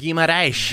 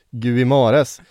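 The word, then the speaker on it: Guimarães: Guimaraes. – Guimaraes. – Guimaraes.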